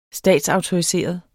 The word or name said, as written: statsautoriseret